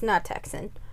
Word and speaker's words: Nachthexen: I wrote how to pronounce it in, and I was right. It's Nachthexen. I did good.